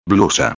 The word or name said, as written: blusa